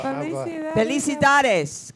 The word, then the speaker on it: Felicidades: Felicidades. I don't want to sound like one of those people running for president who can't speak Spanish. – Felicidades.